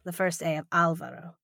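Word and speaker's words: Álvaro: I was I was looking him up while I was waiting before, and I think his name is pronounced Alvaro, not Alvaro.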